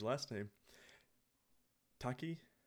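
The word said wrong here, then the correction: Takei: from uh, Takai. Takai from uh, I always do that from uh, Star Trek.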